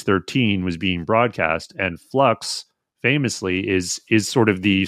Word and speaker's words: Flux: It is actually called Flux. – My mistake. Oh, yes. – Flux. You've got to pronounce it in a Sheffield accent. It's Flux.